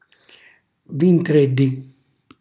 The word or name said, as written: vingtrelli